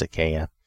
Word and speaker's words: Achaia: Now this Achaia, or I may be saying it wrong, Achaia, but I think it's Achaia.